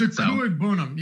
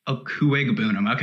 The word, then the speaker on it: Okwuegbunam: It's Okwuegbunam, yes. But the G is actually pronounced, I believe. – Okwuegbunam.